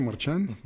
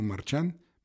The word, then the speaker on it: Marchán: Me pueden encontrar en Spotify o en iTunes bajo Horacio Marchán. Marchán se escribe como marchando sin la O.